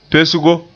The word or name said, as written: pesgo